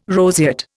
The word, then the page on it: roseate: Indian